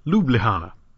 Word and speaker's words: Ljubljana: Okay, here goes. Ljubljana. Ljubljana. That may not sound bad, but there's a lot of soft J's in there.